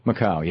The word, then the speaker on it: Macau: And his casinos in, I think I'm pronouncing it correct, in Macau. – Macau, yes.